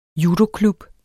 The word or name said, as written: judoklub